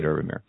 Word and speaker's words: Herbamare: And Herbamir is spelled H-E-R-B-A-M-A-R-E. So it almost looks like Herbamare, okay? But I say it Herbamir.